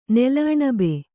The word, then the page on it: ನೆಲಅಣಬೆ: kannada